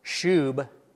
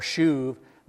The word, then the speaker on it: shub: It's interesting that there is a Hebrew verb, shub. We would spell it S-H-U-B, shub or shuv.